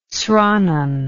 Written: sranan

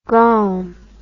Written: graum

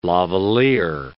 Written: lavaliere

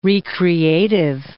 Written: re-creative

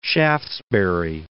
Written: Shaftesbury

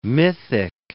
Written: mythic

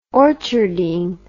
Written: orcharding

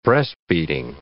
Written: breast-beating

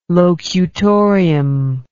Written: locutorium

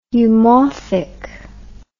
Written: eumorphic

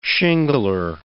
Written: shingler

